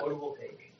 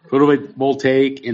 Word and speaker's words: photovoltaic: Photovoltaic. – Yeah, that. Photovoltaic and battery energy storage system,